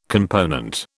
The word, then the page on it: component: British English